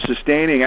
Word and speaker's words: sustaining: And the sustaining, I'm putting the emphasis on the wrong syllable there, the sustaining actually comes from doing all the three things, monitoring, clearing, nourishing, that will sustain and thus maintain them.